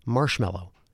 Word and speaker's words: marshmallow: Even though it's spelled M-A-L-L-O-W, mallow, and it looks like it should be pronounced marshmallow, we say marshmallow.